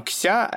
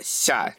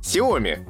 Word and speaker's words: Xiaomi: она не, не кся, она ся, типа, как си, си даже. – Си, как, э, типа, и у нее был пример как сиськи. Вот, и она говорит, поэтому правильно говорить Xiaomi.